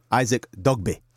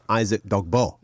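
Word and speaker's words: Dogboe: And he told me how to pronounce his name properly. Isaac Dogbe. – Dogbe. – Not Isaac Dogbo. – Wow, As it is written.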